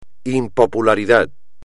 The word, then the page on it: impopularidad: espagnol